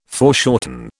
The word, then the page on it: foreshorten: British English